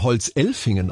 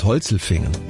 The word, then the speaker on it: Holzelfingen: Den würde man Holzelfingen aussprechen. Wegen der Elfen oben auf der Alp. Nee, der heißt Holzelfingen.